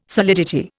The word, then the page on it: solidity: Indian